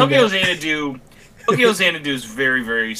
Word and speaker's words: Xanadu: Tokyo and then X. No, yeah, X A N A D U. – Oh my god. Bro, that's Xanadu. Yes, Tokyo Xanadu is a Falcom RPG.